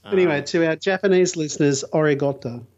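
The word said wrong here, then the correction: arigatou (ありがとう): How was the pronunciation there? – Terrible. – Oh, uh, what would be, give us your best. Well, how should it be said, Paul? Uh, Nihonjin no minasama, arigatou gozaimasu.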